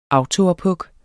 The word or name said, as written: autoophug